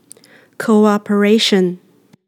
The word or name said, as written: cooperation